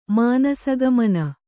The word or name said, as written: ಮಾನಸಗಮನ